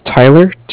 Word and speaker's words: Tyler: My name is Tyler, T-Y-L-E-R. – Mm-hmm. – And I'm calling with Cardiff. It's spelled C-A-R-D-I-F-F, as in Frank.